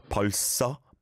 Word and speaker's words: 벌써: 벌써, 벌써. Yeah, because it's a 상시옷 on the next syllable. There is a bit of a s on the first syllable at the end, right?